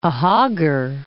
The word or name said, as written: Ahaggar Mountains